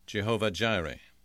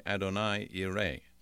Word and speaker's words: Jehovah Jireh: English people tend to say Jehovah Jireh. However, that's very, very remote from what it sounds like in Hebrew. Just let me pronounce it in Hebrew. In Hebrew, they would say Adonai Yireh.